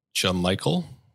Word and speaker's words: Jamichael: So I'm going to choose Jamichael. Tell me, please tell me that's how. – I believe that's it. – Correct. Yeah. – I know that's how he pronounces it.